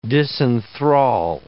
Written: disenthrall

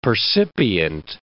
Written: percipient

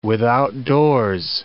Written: withoutdoors

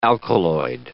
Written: alkaloid

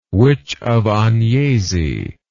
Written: witch of agnesi

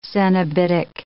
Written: cenobitic

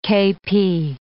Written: KP

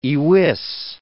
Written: iwis

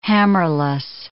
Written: hammerless

